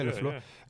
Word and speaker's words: LaFleur: That's in the LaFleur era. The LeFleur, does that say it right? Yeah, LeFleur.